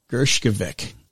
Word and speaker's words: Gershkovich: Gershkovich.